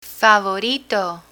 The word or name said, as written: favorito